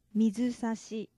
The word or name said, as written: mizusashi